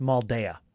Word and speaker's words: Maldaya: His name has Maldaya in it that should also be pronounced Maldaya. – Okay. – D-A-Y is day. Add an A to the end of day. What do you get? – Daya.